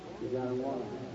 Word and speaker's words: Yagottawanta: It's spelled YAGOTTAWANTA. Did you write that down? What's that say? – You gotta wanna. – You gotta wanna. Isn't that an Indian word?